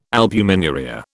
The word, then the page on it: albuminuria: British English